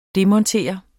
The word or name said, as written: DEMONTER